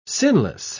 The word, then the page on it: sinless: British English